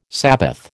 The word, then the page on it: sabbath: American English